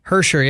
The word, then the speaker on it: Hischier: Nico Hischier? – Hersher, yeah, with, with the New Jersey Devils.